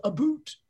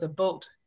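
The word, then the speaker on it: about: So in Canada, it took me a long time to realize everyone goes a boot. It's like, it's not a boot. It rhymes with, in Canada, it rhymes with oats. So it's a boat.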